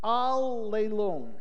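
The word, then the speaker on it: allelon (ἀλλήλων): The phrase derives from a Greek word which is pronounced allelon, and it means one another or each other.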